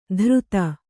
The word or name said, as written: ಧೃತ